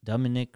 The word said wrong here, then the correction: Dominican: Republic, Dominican.